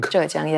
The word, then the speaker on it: Zhejiang: And we discussed that with company Min from the province Zhejiang. – Zhejiang, yes, right.